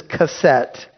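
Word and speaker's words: keset (קשת): "I set my bow," the Hebrew word is keset. You could spell it Q-E-S-E-T.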